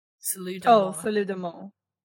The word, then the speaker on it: Salut d'amour: I don't speak French. – Spell it. – S-A-L-U-T. – Yep. – D apostrophe A-M-O-U-R. – Salut d'amour. – There we go.